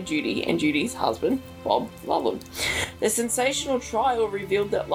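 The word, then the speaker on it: Lulham: How do you say that again? – Lollum. – Lollum, right, that English thing, Lollum, who was treated for thallium poisoning in 1952.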